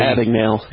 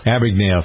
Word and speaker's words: Abagnale: I believe it was Abagnale. Is that how you pronounce his name? Real story. – Abagnale. – Abagnale, thank you.